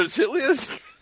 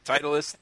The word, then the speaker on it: Titleist: Oh, is it a titlius? Titleist? Sorry, I'm saying titlius. – Beavis and Butthead reference right there? – Yeah, it was. I only know how to say it the way Beavis and Butthead say it, not the real way.